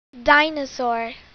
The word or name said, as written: dinosaura